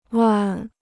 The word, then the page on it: 万: wàn